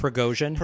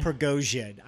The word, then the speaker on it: Prigozhin: Prigozhin. – Prigozhin.